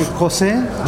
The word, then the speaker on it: Jose: Okay, Jose, J-O-S-E.